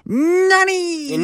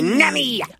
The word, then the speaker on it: Nani: Say it properly. – Nani! – Nani!